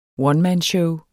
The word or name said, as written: onemanshow